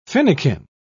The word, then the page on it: finikin: British English